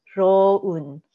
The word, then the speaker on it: Rowoon: Rowoon is actually pretty, I mean, that's pretty accurate. It's Rowoon.